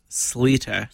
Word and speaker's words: Schlueter: Oh, it's spelled really weird, but it's pronounced Sleeter. – Sleeter. – But it's like S-C-H-L-U-E-T-E-R.